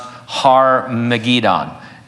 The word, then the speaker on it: Har-Megiddon: Armageddon is derived from the original Hebrew word pronounced Har-Megiddon, and so it has to do with this valley of Megiddo.